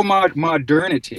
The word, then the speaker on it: modernity: And then there was another word that looked like it had been created from another word, I want to say modity. I don't think I have that pronounced correctly. But it's also in the introduction. – Oh, modernity. – Thank you, modernity. – Yes, modernity.